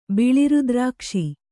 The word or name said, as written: ಬಿಳಿರುದ್ರಾಕ್ಷಿ